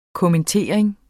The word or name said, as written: kommentering